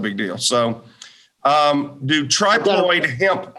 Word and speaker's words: triploid: The triploid plants. – You call it trip or tri? Obviously, I don't even know how to pronounce it. So it's triploid.